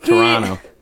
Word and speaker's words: Toronto: No, second T. Second T does not get pronounced. Toronto.